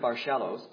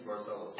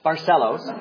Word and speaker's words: Barcellos: Barcellos, however you pronounce his name. Maybe I'll say cello because it sounds like a cello. Barcello. Is that right? – No, I think it's Barcellos. – Dang it. Barcellos.